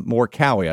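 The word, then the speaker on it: Morikawa: He's an American, USA kid, and more Cowie, I think is the way you say his name.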